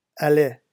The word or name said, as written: allait